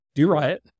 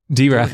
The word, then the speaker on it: Doriot: And that is spelled D-O-R-I-O-T. – Do right. – Do right. – Do right. – Do right. Do right. Do right. Yes.